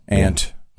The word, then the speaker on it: aunt: So it's a silent U then? It's just like they just put the U in there for, you know, no real reason. – Yeah, it pretty much is. – Aunt.